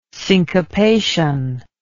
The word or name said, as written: syncopation